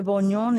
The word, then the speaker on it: bourguignon: Is that the pronunciation, I think? – De bourguignon, is that what she said? Bourguignon. – Yeah. Bourguignon. – Bourguignon, which is burgundy, yeah. Yeah, there you go. That sounds pretty good.